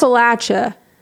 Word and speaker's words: Appalachia: And it's pronounced Appalachia, not that other way people say it.